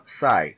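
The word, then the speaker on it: XI: Y, una saga que no tengo idea de qué se trata, que es de Sony, que se llama Sai. Se escribe X y, latina. Sai. Como si fuera 11 en romano.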